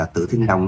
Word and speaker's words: Tử Thiên Long: Rồng Tử Thiên, bây giờ át đọc là Tử Thiên Long nha.